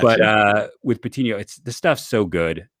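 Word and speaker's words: Patiño: And if you're comparing centerpieces, I mean, I still think Patino blows him away, and I'm probably butchering his name again. – I think it's Patino. – Patino. Makes sense. There's the Enya, right? – Yeah.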